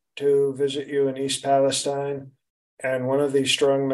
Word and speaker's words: Palestine: It's pronounced Palestine. – Okay. – They don't want to be confused with, Middle Eastern people or Jewish people.